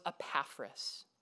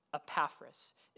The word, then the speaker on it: Epaphras: And one of the people, who's very important for our study of Colossians, who heard that word was Epaphras. Epaphras. It's spelled E-P-A-P-H-R-A-S. R-A-S.